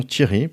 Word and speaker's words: Thierry: parce qu'il n'y avait pas un Thierry oui c'est Thierry T E R R Y mais pas T H I E R R Y donc les gens disaient mais comment tu prononces et donc c'est theory theory theory